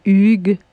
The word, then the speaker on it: Hugues: C'est surtout H-U. S'il y a comme Hugues. – Oui, Huguette. – Well, maybe one can, yes, yeah, silent. Ash, silent H.